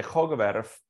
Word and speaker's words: Hoogerwerf: Hoogerwerf. I mean, if you're Dutch, you would say Hoogerwerf, but otherwise, you say it however you want.